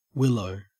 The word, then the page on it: willow: Australian English